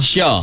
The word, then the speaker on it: Sha: S-Y-A. – Syah. – Tapi ni S-H-A. – Oh, S-H-A. – Syah juga bunyi. – Syah juga. Tapi tetap bunyi Syah.